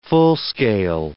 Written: full-scale

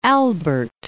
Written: Albert, Lake

